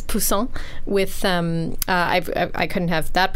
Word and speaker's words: poussin: Okay, I'm going to pronounce this wrong because I keep wanting to say it like the French word for fish, but I think it's pronounced poussin.